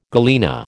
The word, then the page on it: galena: American English